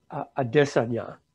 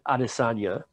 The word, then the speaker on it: Adesanya: There's a guy named Israel Adesanya. All the announcers used to pronounce it Adesanya because they didn't know him. They didn't know how to pronounce it.